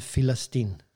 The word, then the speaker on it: Filistin: Eh, og den delen får nå navnet Filistin av araberne. Så de tar jo egentlig bare det romerske navnet Filistin. De kan ikke uttale P i begynnelsen av et ord, så det blir en F.